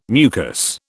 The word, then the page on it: mucus: British English